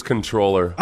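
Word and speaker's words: comptroller: The correct pronunciation is controller. – Oh, wow. Why do they spell it like that? – It says comp.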